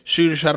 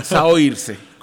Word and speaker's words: Saoirse: Pero, pero no es Saoirse, como se escribe.